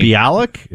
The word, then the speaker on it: Mayim Bialik: Mayim Bialik? Is that right? – Yeah.